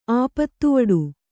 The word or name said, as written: ಆಪತ್ತುವಡು